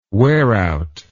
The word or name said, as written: wearout